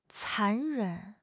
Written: 残忍

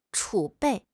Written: 储备

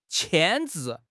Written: 牵制

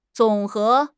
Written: 总和

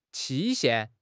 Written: 期限

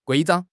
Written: 规章

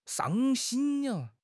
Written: shang1 xin1

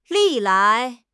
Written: 历来

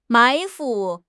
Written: 埋伏

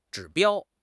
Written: 指标

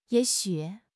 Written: ye3 xu3

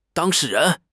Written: 当事人

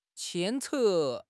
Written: 牵扯

